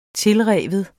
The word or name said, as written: tilrive